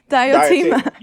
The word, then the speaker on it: Diotima: How you say her name? – Oh, Nyanti. Oh, Diotima. – No, huh? – Diotima.